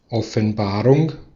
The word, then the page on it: apparition: France; /a.pa.ʁi.sjɔ̃/